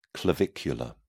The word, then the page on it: clavicular: British English